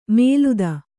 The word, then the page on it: ಮೇಲುದ: mēluda